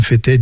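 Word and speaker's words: Fetet: Fétet, 10 variétés.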